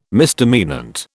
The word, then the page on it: misdemeanant: British English